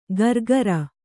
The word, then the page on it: ಗರ್ಗರ: gargara